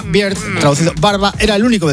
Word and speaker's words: Beard: Con la cerveza y el oso, Beard. – Ahí está. – Beard.